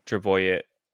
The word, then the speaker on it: Trbojevic: Am I saying that correctly? – Sure. – You got to put a tr at the end. – Trbojevic. I should know. They're Serbian, aren't they? – Trbojevic.